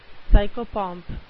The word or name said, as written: psychopomp